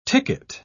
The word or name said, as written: ticket